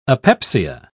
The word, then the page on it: apepsia: British English